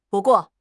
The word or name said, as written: bu4 guo4